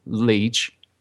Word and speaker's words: Lech: The report strengthened accusations by rightist groups that investigators ignored evidence of outside involvement and prompted opposition leader Jarosław Kaczynski, the twin brother of Lech, as I mispronounced Leah before,